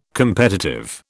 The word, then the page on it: competitive: British English